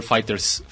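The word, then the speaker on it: FighterZ: Ajá, y Bandai dijo Nel, o sea, se pronuncia Fighter C, no C, Fighters, perdón. Se pronuncia Fighters, no Fighter C, no Fighter Z, no Fighter of C.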